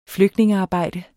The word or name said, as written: flygtningearbejde